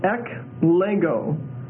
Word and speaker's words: eklego: The Greek for chosen is eklego, E-K-L-E-G-O. And that's a long O, eklego.